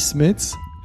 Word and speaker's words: Smets: Donc ça, ça, se dit Smets.